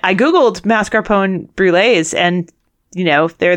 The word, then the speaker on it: mascarpone: And they were looking for mascarpone, as Kent pronounced it. Although I hear people say mascarpone around here usually. – Yeah, all the time. – I guess probably the Italians pronounce the E at the end because that's how Italian works.